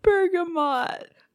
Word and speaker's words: bergamot: Wait a minute. It's not bergamot. Do you know what vagina scent is supposed to be? – No, it's bergamot. – I've been saying that wrong. – Bergamot, not bergamot.